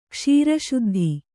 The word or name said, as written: ಕ್ಷೀರಶುದ್ಧಿ